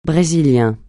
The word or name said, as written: brésilien